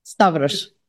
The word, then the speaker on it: Stavros: Stravos, is that wrong? – Stavros. V and then Stavros.